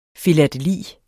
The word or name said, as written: filateli